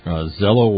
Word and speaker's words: Zello: It's called Zello Walkie Talkie, Z-E-L-L-O Walkie Talkie. And that's the name of it. So that's how you say it, Z-E-L-L-O Walkie Talkie.